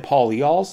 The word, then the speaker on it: polyols: And then, you said polyols? Is that how you pronounced it? – Polyols, got it.